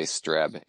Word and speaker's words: Streb: I say Streb. It's tough though, because you got double consonants. So it's Travis Streb and you got two S's.